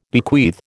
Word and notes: bequeath: American English

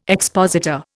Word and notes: expositor: Indian

